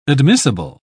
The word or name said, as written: admissible